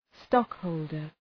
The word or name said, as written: stockholder